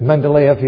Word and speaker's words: Mendeleev: Yeah, how do you say his name? – I say Mendeleev. – Mendeleev, yeah.